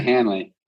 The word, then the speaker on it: Hanley: That's it, Hanley.